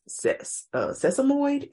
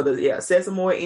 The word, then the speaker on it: sesamoid: They said it, in addition to the right toe sprain that we knew about, it was also a sesamoid injury. I believe that's how you pronounce it. – Yes, ma'am. – Okay, so sesamoid injury that he's experienced, and he has started the revamping process, so that's the good news.